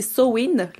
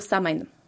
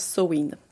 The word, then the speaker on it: Samhain: Mais bref, c'est écrit Samhain, mais ça se prononce Sowin tu vas savoir.